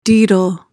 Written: daedal

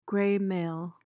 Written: graymail

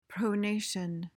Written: pronation

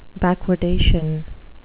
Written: backwardation

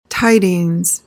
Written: tidings

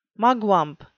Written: mugwump

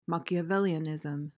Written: Machiavellianism

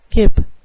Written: kip

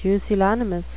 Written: pusillanimous

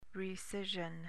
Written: recision

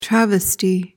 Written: travesty

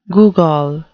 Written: googol

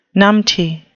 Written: numpty

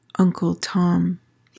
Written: Uncle Tom

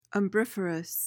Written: umbriferous

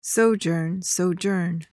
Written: sojourn

